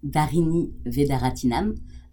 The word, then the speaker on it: Dharani Vedaratnam: I pronounce it the French way, which is Dharani Vedaratnam, but the name is originally from Tamil, which is your mother tongue, so I'm incapable of pronouncing it the Tamil way.